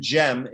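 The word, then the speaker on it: Cem: Bayezid versus Sem. Can you talk about their relationship and what it was like Yeah. So Bayezid and Jem is how the name is pronounced.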